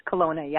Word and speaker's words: Kelowna: Kelowna. Is that how you say it? – Yeah, that's Kelowna. – It. It's Kelowna, yeah.